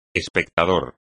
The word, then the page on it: espectador: espagnol